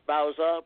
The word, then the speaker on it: Bauza: Yeah, it's pronounced Bowser, but a lot of people mispronounce it as Bowser, the guy from Sha-na-na.